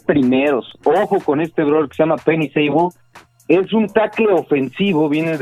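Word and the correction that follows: Penei Sewell: Penny Sua, ¿no? es la pronunciación correcta.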